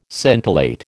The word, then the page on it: scintillate: American English